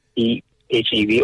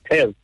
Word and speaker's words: PHEV: I would just say PHEV or just plug-in hybrid, which is, that's mine. But if you want to say PEV, I mean, that sounds cool.